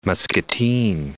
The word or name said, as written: Muscatine